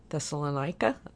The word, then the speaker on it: Thessalonica: And again, this is a letter he wrote to a church that he started in the city of Thessalonica.